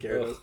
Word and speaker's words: Gyarados: Gyarados.